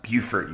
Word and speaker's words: Beaufort: I was about to say, it's pronounced Beaufort, you swine.